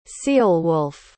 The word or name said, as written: ceolwulf